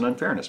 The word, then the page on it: unfairness: American English